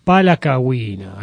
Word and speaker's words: Palacagüina: Nos vamos a Nicaragua, al señor Carlos Mejía Godoy, junto con los de Palaguina. Es así, ¿no? Palacagüina, ahí está mejor.